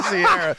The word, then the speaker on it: Ciara: Yeah, Sierra.